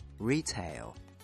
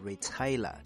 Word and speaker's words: retail: 对于这个单词，我们当然要先讲一下它的原型 Retail。注意它的发音，我听过很多同学容易读错，读成 Retail 或者是 Retailer，这是错误的，一定要注意重音在前面。